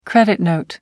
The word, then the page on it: credit note: American English